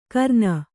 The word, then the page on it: ಕರ್ನ: Kannada